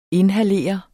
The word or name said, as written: inhalere